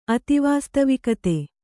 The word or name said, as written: ಅತಿವಾಸ್ತವಿಕತೆ